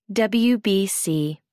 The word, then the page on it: WBC: American English